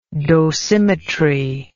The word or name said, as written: dosimetrist